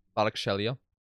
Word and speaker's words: Kvaratskhelia: Kvitsa Kvaratskhelia. – Kvaratskhelia.